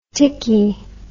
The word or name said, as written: ticky